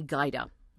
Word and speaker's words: Gajda: My name is Amy Gaida. My last name is spelled G-A-J-D-A.